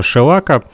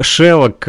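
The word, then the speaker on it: шеллак: шелака. Э, научили меня, как правильно нужно говорить, потому как э, я раньше говорил «шелок», э, э, но правильно говорить «шелак»?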